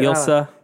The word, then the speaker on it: Ilsa: Rebecca Ferguson is Ilsa. Is that right? Is that how you say it? – Ilsa.